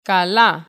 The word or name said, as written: καλά